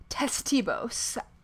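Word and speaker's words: Ctesibius: But this is one of those Greek names with a silent C followed by a consonant. Oof. So Ctesibos, I'm going to go with.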